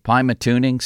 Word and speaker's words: Pymatuning: This is coming to, tell me if I'm pronouncing these right, Pymatuning State Park.